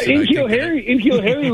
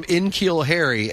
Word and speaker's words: N'Keal Harry: Because you continue to call him Enkeel Harry, and I've always thought it was N'Keal Harry.